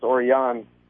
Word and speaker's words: Orion: Dave Orion. Well, I mean, you want to be like super duper ultra mega correct, it's Orion.